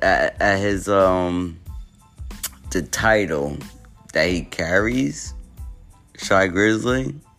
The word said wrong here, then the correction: Shy Glizzy: Shy Glizzy. I keep on saying his name wrong.